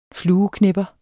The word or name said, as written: flueknepper